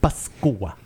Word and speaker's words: Pascua: So we say Pascua.